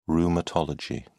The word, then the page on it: rheumatology: British English